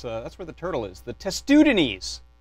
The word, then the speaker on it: Testudines: but a robot on YouTube told me it was pronounced testudines. And that is our 10% coupon, testudines, which is the order of reptile that our friend the turtle hangs out in.